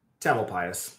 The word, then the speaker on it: Tamalpais: but this Mount Tamalpais, is that how you say it? – Tamalpais.